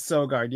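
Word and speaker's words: Søgaard: But you know who's playing well in Ottawa is Sogard. Is that how it's pronounced? Sogard in the minors? – Mads Sogard. Yep.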